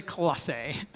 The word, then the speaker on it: Colossae: Now on to Colossae, a small city in modern-day Turkey, with about 14 different ways to pronounce its name. But I'm going to say Colossae.